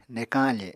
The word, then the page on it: nekañ afe: Apatani